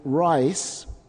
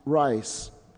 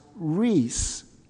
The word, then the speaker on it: Rice: The last name, Rice, was spelled in Germany R-E-I-S-S, pronounced Rice. In this country, it would have been pronounced Reese. So my parents changed the spelling to retain the pronunciation.